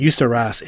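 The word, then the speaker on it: Jeszcze Raz: So if you want to look them up, you pronounce it Justa Raz. It's J-E-S-Z-C-Z-E and then R-A-Z from Montreal.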